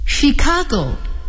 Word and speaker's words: Chicago: Chicago. He lives in Chicago. 今天挺有意思，一个字母组合 CH 有三种发音，它们是 CH、 K 和 SH。